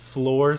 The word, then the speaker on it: Fleur: But it's spelled like F-L-E-U-R. – Yeah. – Salon. That's weird. – Yeah, so it's not like floor, like this is the ceiling and then the floor.